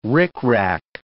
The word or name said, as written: rickrack